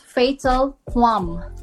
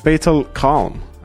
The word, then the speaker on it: Fatal Quam: It's pronounced as Fatal Quam. – Okay, so I've been saying it right, because I was worried. Because you're saying it right. You are saying it right. – Because a friend of mine said Fatal Calm, and I was like, I don't think that's right.